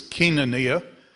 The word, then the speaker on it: koinonia: In the south, that's pronounced koinonia. But it, in Greece, it's koinonia, which is the primary word translated church.